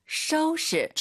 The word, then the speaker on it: 收拾: 收拾, 지금 오유 발음이 연달아 두번 있죠? 떠우 셔우예요. 절대 또 쇼우 아닙니다, 여러분.